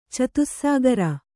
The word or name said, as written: ಚತುಸ್ಸಾಗರ